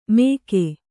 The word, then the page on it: ಮೇಗೆ: Kannada